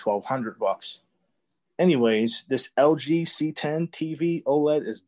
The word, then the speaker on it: CX: LG CX pronounced C10.